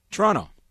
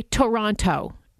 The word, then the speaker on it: Toronto: Toronto. – Yeah. So a tourist will say Toronto and really sound out that second T, right?